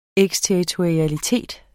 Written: eksterritorialitet

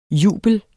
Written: jubel

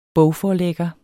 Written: bogforlægger

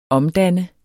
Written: OMDANNER

